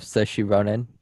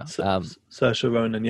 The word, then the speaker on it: Saoirse Ronan: I have Saoirse Ronan. I think I said it right. – Yeah. Saoirse Ronan.